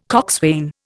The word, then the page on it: coxswain: Indian